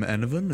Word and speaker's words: Enevun: I'm Enovan, a streamer here on Twitch.